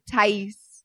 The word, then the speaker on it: Tahys: Fit by Tahys. – Fit by Tahys. – Yes. – Let's spell that. T-A-H-Y-S.